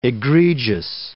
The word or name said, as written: egregious